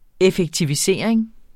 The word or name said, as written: effektivisering